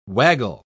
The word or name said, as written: waggle